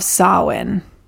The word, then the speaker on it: Samhain: Halloween originated from the Celtic festival of Samhain, which is spelled S-I-M-H-A-I-N. – Yes. – And is bizarre, but that's how it's pronounced.